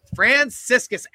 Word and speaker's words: Franciscus: Franciscus.